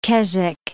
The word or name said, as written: Keswick